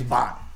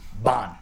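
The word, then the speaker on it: Bähn: Oh, my name's Bon. Bon. B-A-H-N.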